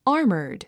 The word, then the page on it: armored: ɑ’ː r mə r d; アーマード